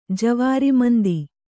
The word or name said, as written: ಜವಾರಿಮಂದಿ